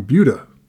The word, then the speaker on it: Buda: Is it Buda or Buda? – So it's Buda, Buda, but everybody on the football team pronounced it Buda. – Okay. – So yeah. Like I would always hear that. James Rossi from Buda, Texas. I'm like, it's Buda, but whatever.